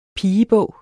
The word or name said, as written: pigebog